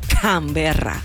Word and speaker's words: Canberra: C, Canberra. – Es Can, ¿ok? – ¿Cómo se dice? – Canberra.